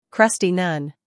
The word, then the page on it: crusty: ˈkrʌsti